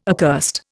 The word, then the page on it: accursed: Indian